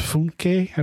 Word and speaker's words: Funke: His name is Funke, I believe is how it's pronounced. It's F-U-N-K-E.